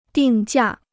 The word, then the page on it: 定价: dìng jià